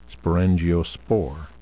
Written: sporangiospore

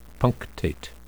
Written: punctate

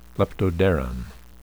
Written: leptoderan

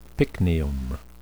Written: pycnium

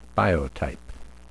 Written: biotype

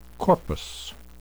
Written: corpus

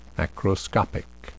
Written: macroscopic

